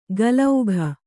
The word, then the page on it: ಗಲೌಘ: galaugha